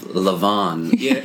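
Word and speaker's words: levain: And you had said you had used the correct pronunciation of Levon.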